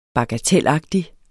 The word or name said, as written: bagatelagtig